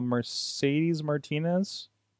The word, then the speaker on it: Mercedes Martinez: Um, and why do I feel like I'm saying her name wrong? Uh, Mercedes Martinez. Did I say that right? Okay. Yes.